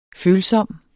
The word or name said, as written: følsom